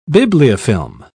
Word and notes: bibliofilm: British English

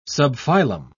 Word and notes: subphylum: British English

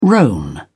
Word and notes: roan: British English

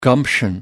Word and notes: gumption: American English

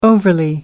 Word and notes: overly: American English

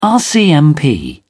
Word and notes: rcmp: British English

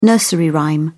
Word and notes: nursery rhyme: British English

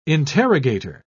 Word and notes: interrogator: British English

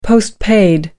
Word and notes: post paid: American English

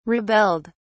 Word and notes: rebelled: British English

